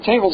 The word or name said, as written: tables